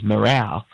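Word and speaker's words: Marou: And technically, the way you pronounce Maroon is morale.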